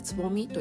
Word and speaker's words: 蕾: 草冠に、えーと、雷っていうふうに書いてつぼみと読みます、うん面白いですよね。